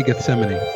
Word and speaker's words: Gethsemane: 2020 Gethsemane.